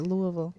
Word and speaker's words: Louisville: Am I saying it right? Louisville. – You are.